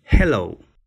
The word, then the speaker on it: hello: 记住，正确的版本应该是 hello、 hello，a 的音 hello。完美发音，张口即来。